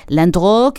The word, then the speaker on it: Landru: Hablamos de Henri Désiré Landreau. Landreau que se escribe Landru